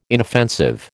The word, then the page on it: inoffensive: American English